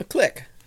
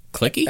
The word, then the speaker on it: clique: C-L-I-Q-U-E. – A click. – It's a click. Some people call it clicky. – A clique. – I never heard that. – No, that's when you're mispronouncing it.